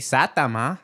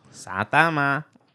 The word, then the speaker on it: Saitama: Saitama. – Oh my god. Your Japanese needs some work. – Saitama. That's how you say it, right? how you say it, Keith? – Facebook. – Saitama. Is that better? – Saitama.